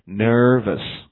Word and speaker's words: nervous: R 的音，所以呢不能读成 verse，读成什么呢？verse， us， OK，然后加起来，这是大家要锻炼的一个语言的语感啊，就是 ner，然后 verse，然后快一点，nervous，好，你跟我读两遍，nervous，OK，、 okay, 那么好，我们接着往下看，after you relax， you see how absurd it all is， right？